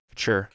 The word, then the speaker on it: Couture: Couturier? – Couture. – Couture, whatever, yeah.